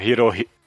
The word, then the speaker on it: Hirohiko: Hirohiki Araki. É. – Hirohiko. – Hirohiko. O que, que eu falei? Ah, Hiki. Ah, tá. Hirohiko. Eu ia falar é, de novo. É o herói rico. Hirohiko.